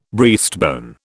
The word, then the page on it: breastbone: British English